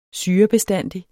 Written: SYREBESTANDIGT

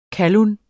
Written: KALLUNERNES

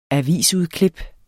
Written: AVISUDKLIP

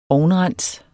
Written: ovnrens

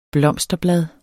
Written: blomsterblad

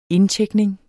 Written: indtjekning

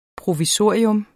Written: provisorium

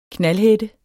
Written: knaldhætte

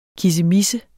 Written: KISSEMISSE

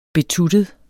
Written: betuttet

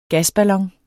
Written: gasballon

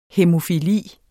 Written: hæmofili

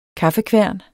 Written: kaffekværn

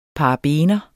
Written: paraben

